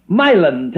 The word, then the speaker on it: Milan: Several years passed, and I met the pastor of the Baptist Church of Milan, Tennessee, and I learned that they pronounced the name of the city with the accent on the first syllable.